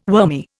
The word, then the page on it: wormy: Indian